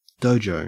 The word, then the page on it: dojo: Australian English